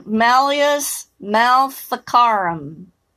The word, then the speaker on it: Malleus maleficarum: So, uh, yeah, Jessica, Malleus maleficarum. I think that's how you pronounce it. Yes, you are correct.